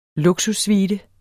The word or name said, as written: luksussuite